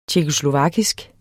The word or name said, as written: tjekkoslovakisk